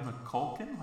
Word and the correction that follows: Macaulay Culkin: Macaulay Culkin.